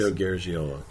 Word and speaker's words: Garagiola: And Joe Garagiola, or how you pronounce it? – Joe Garagiola. – Garagiola. There's so many different ways.